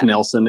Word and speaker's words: Knelsen: The K in, it's not like the K in Knife. – It's yeah. Knelsen. It's mm.